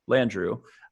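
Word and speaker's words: Landrieu: And in the book, the former mayor of New Orleans, Mitch, and then say his name for me again, so Landru. – I get it right. – Landru.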